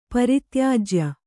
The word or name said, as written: ಪರಿತ್ಯಾಜ್ಯ